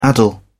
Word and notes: addle: British English

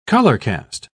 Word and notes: colorcast: British English

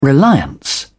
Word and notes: reliance: British English

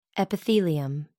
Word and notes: epithelium: American English

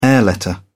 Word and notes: air letter: British English

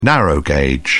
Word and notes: narrow gauge: British English

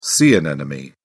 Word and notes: sea anemone: British English

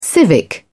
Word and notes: civic: British English

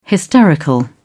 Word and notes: hysterical: British English

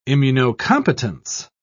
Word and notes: immunocompetence: British English

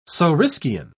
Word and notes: saurischian: British English

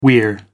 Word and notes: weir: American English

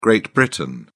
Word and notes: Great Britain: British English

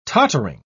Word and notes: tottering: British English

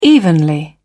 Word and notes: evenly: British English

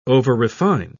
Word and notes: overrefine: British English

